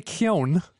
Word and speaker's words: Keoghan: Okay, so Barry Keoghan, which is uh, yeah.